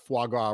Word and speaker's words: foie gras: But I need somebody in the comments section to settle this unexpected dispute that we just popped up on as to whether or not it's pronounced foie gras, or how did you pronounce it? – Foie gras. – Foie gras. It's foie. – You are correct.